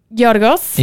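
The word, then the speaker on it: Giorgos: Ähm, Georgos.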